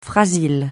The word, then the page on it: frasil: francais